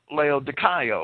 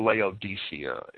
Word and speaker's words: Laodicea: And among the assemblies which Paul is credited as having founded are those at Ephesus and Laodicea, or Laodicea is the common pronunciation.